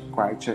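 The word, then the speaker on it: Katwe: eu acho que Quite é essa pronúncia, é K-A-T-W-E, tá bom?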